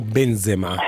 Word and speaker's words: Benzema: Piuttosto mi viene da pensare, datemi Benzema per la nazionale italiana, lo chiamiamo Benzema, senza l'accento sulla A,